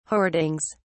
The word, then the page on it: hoardings: British English